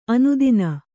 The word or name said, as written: ಆನೆದಿನ್